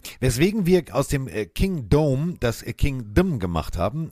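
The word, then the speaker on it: Kingdome: Das alte Stadion der Seahawks wurde meines Wissens nach King Dome ausgesprochen wie der Dome und nicht Kingdom wie das Königreich.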